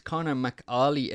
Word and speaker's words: Conor McAuley: It's Conor McAuley. It's just how I say it. – No, Audrey? Yeah, you didn't say it like that, though, a minute ago. At all. – It sounded like Carly, did it?